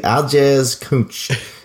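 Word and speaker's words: Aljaž Kunc: Or no, it's K-U-N-C. I want to pronounce that correctly for everyone. K-U-N-C. Aljaz, Aljaz Cooch.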